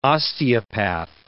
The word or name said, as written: osteopath